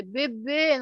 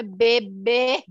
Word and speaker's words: bebê: Até a pronúncia, você precisa usar E é fechado. É bebê, não é bebê.